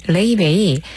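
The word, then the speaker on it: Laufey: 레이베이 이 철자하고 발음이 굉장히 달라서 초반에 이제 막 우리나라에 소개될 때 여러 가지로 이렇게 어떻게 발음해야 될지 좀 난감했었던 기억이 있는 가수예요. L-A-U-F-E-Y를 쓰고 레이베이라고 발음을 하고 아이슬란드 출신의 재즈 가수입니다.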